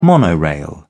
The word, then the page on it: monorail: British English